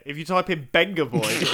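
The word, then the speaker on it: Vengaboys: It's the Benga Boys. It's pronounced. It's not the Venga Boys.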